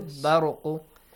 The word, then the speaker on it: البرق: And the next one, bar ra, the letter ra must be heavily pronounced because it's a fatha sound before. Baruqu yakhtafu absarahum.